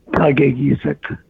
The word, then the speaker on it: Kakaygeesick: Kakaygeesick.